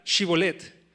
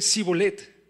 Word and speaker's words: shibolet: Dice, entonces, cuando les preguntaban ellos si eran efrateos, ellos decían, este, y decían sí, ellos decían, di pues la palabra shibolet, pero ellos decían sibolet, porque no podían pronunciarla correctamente. Entonces estas personas este, de Efraín no podían pronunciar bien la SH y hacían un sonido que era diferente y a través de eso los de Galat definían de dónde es que provenían.